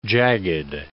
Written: jagged

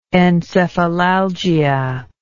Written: encephalalgia

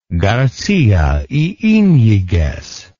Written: garcia y iniguez

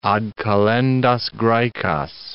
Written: ad kalendas Graecas

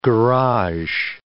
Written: garage

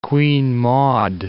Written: Queen Maud Land

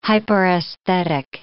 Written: hyperesthetic

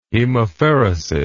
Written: hemapheresis

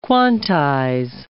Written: quantize

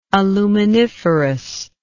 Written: aluminiferous

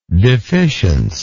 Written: deficience

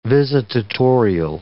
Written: visitatorial

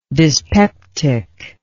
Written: dyspeptic

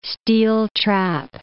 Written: steel-trap

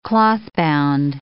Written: clothbound

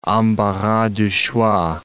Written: embarras de choix

